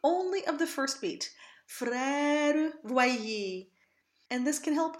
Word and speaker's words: frère: And in the very beginning of this recitative, we have the phrase frère voyer. Even though the schwa ends up on a beat, it's very important to unstress this syllable. In normal speech, this syllable wouldn't even exist.